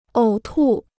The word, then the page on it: 呕吐: ǒu tù